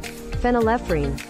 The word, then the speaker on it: phenylephrine: It's called, how the hell do you pronounce that? – Phenylephrine. – Phenylephrine. That's a lot of syllables.